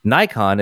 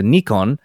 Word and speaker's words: Nikon: Nikon is something close to Nikon, where the apostrophe indicates a very slight pause and the ko is slightly nasalized.